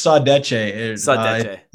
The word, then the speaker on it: saudade: Was it seduce? – It's saudade.